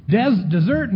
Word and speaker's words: dessert: Desert now, that's a different word, two S's. And the emphasis is on the ert instead of the des.